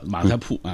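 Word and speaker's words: 马家堡: 哎，北京都这么叫马家铺啊。当然也有一些地方把这个铺读饱是吧？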